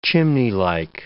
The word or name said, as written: chimneylike